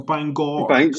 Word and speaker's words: Bangor: Was there any particular reason that drew you to Bangor or Bangor? – Bangor.